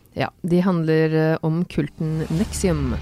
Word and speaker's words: NXIVM: Nexium, som heter, altså N-X-I-V-M. – Ja, så det litt sånn, jeg synes det var vanskelig riktig hvordan jeg skulle uttale det, ja. Men I dokumentaren sier de Nexium.